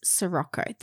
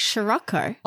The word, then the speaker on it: Sirocco: It clearly is Sirocco. That's how it's spelled. Now, I want everybody to go back and watch Captain Sandy try and say it because she cannot say Sirocco. – Really? – She goes, Sirocco.